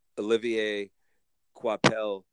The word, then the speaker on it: Olivier Coipel: I'm gonna pronounce this as if I'm French. Olivier Coipel. I think that's how you say his name. – That was perfect French.